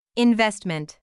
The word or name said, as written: investment